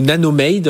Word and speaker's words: Nanomade: Vous êtes président fondateur de Nanomad, ou Nanomade, on va le prononcer à l'anglaise, 15 années de R&D.